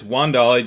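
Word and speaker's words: Wan'Dale: It's Wandale, just like it's Rondale. – Okay. Rondale and Wandale.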